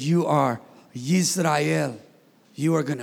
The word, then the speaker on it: Yisrael: In Hebrew, it's you pronounce it Yisrael with a Y.